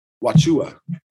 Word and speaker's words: Wauchula: So you're in Tampa, and it's called Wachucha? – Wachua. – Wachua. Let's do this. – It not is spelled Wauchula.